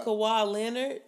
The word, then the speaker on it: Kawhi Leonard: Well, Kawhi Leonard.